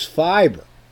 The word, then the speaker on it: Fibre: It's Nippy Brit is saying, I'm pretty sure it's pronounced the same as fiber, says Nippy.